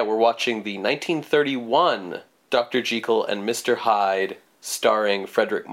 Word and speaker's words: Jekyll: I'm going to really try to stick to the original pronunciation. We've been really bad about it, but it is Jekyll and Hyde is the proper original pronunciation, and we have been bad at being consistent about it.